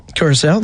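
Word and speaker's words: Curaçao: I'm going to pronounce this wrong, but Curacao? – Curacao, that's it. Yep.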